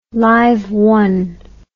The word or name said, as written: live one